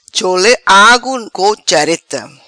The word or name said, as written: Chole agu co chareta